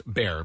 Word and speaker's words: Bexar: Bear County comes from a Mexican word, San Antonio de Bejar, and it used to be spelled B-E-J-A-R, and somehow along the way they got an X in there, but it's always been pronounced bear because it's in a Mexican word, derivation.